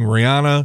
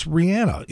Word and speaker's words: Rihanna: And by the way, that is the correct pronunciation of her name. For years, people have been saying Rihanna. A couple of months ago, she said, it's Rihanna. You know, like the song Rhiannon, like the witch?